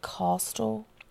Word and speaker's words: Kostial: Coastal or Coastal, but from what I've seen, people pronounce it Coastal. – Okay. – But it's K-O-S-T-I-A-L. – K-O-S-T-I-A-L? – Mm-hmm.